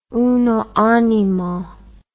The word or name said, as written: uno animo